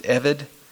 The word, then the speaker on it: ebed: The Hebrew word for slave, eved or E-B-E-D, it's pronounced eved, appears in the Old Testament 1,089 times.